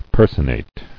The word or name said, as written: Personation